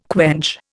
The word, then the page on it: quench: Indian